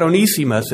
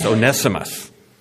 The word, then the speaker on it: Onesimus: And the name that's coming up later is not Onesimus, it's Onesimus.